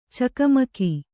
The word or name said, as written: ಚಕಮಕ